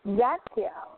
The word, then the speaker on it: Zadkiel: Zachiel. Z-A-D. Well, actually, this could be the angel name for Ezekiel because it's spelled Z-A-D-K-I-E-L.